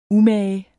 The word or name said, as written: umage